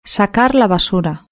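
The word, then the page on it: sacar la basura: Spanish (Spain)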